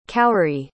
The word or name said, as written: khoury